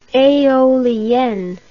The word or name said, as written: eolienne